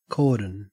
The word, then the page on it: cordon: Australian English